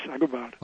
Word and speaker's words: Agobard: Is that how you pronounce his name, Agobard? – Yes, Agobard.